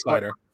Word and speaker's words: Seider: Yep, and then so I really hope I'm saying this right. Cider. – I think it's Cider.